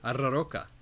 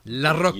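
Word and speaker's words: Larroca: We're going to go ahead and move along to Alien Number 5 by Philip Kennedy Johnson and Salvador La Roca. – That was beautifully pronounced. – La Roca. Beautiful.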